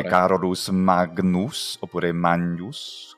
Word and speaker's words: Magnus: GN, si pronuncia, ovviamente, Magnus come a Magna, a Roma, che sta a Magna.